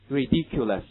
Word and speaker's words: ridiculous: 对，这也是这个 ou 呢，这样一个字母组合没有出现字母啊，千万不要加卷舌音，不要读成什么 that's ridiculous， OK，这就很难听了， that's ridiculous 就不正确了。 ridiculous 记住，没有卷舌音在这个地方。